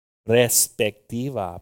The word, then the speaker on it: perspectiva: Pero a veces, hermanos, eso no es nuestro perspectivo. How do you say it? Perspective? Perspectivo. Ay, ¿dónde están los mexicanos hoy? No los dejamos entrar. Perspectivo. ¿Cómo? Perspectiva. Perspectiva. Ok.